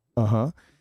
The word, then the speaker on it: Balenciaga: Is that how you pronounce Balenciaga. – It? Balenciaga.